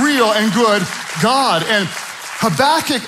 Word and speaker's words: Habakkuk: Say it again, Habakkuk. I'm gonna have you type it online, but I'm gonna tell you how to spell it. Type it online, Habakkuk, H-A-B-A-K-K-U-K, Habakkuk.